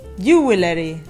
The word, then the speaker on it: jewelry: En inglés decimos... jewelry, jewelry, jewelry, jewelry, jewelry. – Jewelry. – Yeah, so it's got 3 syllables.